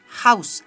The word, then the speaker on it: Haus: La H al principio es una J. Sonido J. Haus, Hausaufgaben.